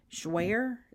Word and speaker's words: Jouer: Okay, here's a question that asked me how I like the, I can't say it, Jouer? Is that how you pronounce it? J-O-U-E-R? – Yes.